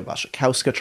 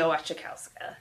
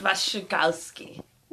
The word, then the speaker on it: Wasikowska: And Mia Wasikowska. – Yeah. I like to say Wasikowska. – Wasikowski. – You mispronounced it there.